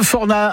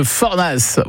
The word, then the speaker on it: Fornas: Annie de Saint-Nizier de Forna. Alors, je ne sais pas si on prononce le S à Forna ou pas. On le prononce, Annie ? – Oui. – Ah, de Fornas.